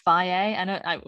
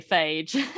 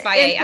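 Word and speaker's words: Fage: I used to have I think it's pronounced Faye. We just say Phage. – I think it is Faye. I think on the side it says, F-A, So in Greece, obviously it's Greek.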